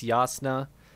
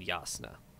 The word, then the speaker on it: Jasnah: It's also spelled with a J. So both J's are with a Y. So it's Yakaved and Jasnah.